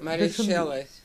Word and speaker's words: Mary Shelley: Exato, e, e, e, cujo nome se pronuncia exatamente, portanto eu acho que o E deve ser aberto. Mas pronto. – Mary Shelley. É aberto? Sim.